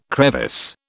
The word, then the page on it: crevice: British English